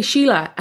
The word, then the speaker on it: Síle: So I was saying, and then they said, Hey, Síle. And he goes, Oh my God, okay, thank God, that's how you say your name.